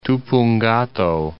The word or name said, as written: Tupungato